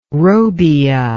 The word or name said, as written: robbia